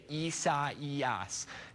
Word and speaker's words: Isaias: And yes, a lot of people have been kind of butchering that pronunciation. That is how you say Isaias.